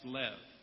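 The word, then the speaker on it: lev (לב): And it's essentially the, the letters we have for L and B, and it's pronounced lev.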